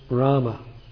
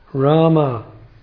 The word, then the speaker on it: Rama: Srila Guru Maharaj, Rama is the true pronunciation, but if one says Ram, that does not mean it has no value. It will have some effect.